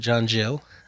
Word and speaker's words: Gill: And I like the way that John Gill, some might pronounce it John Jill, in his commentary summarized it.